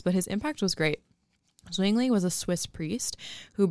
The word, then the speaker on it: Zwingli: Zwingli. – It's like swinging, but with a Z. Zwingli.